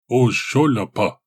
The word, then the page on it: Ozh-sho-lapah: Galindri